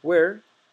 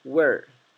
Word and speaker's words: where: Otra vez recuerden que pueden pronunciar where o where, ¿sí? Pueden pronunciar como si fuera el sonido de la H o el sonido de la U, where, ¿bien? Ambas pronunciaciones son aceptadas.